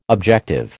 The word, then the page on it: objective: American English